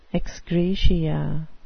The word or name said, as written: ex gratia